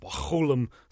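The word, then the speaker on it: Mechoulam: Actually, you know, he's Israeli, so you actually have to kind of hawk up a loogie to say it correctly. McCullum, something like that.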